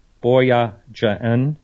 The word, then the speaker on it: Boyajian: And Tabitha, I'm going to try to pronounce her name right, Boyajian, very similar to that.